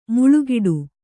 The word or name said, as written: ಮುಳ್ಳುಗಿಡ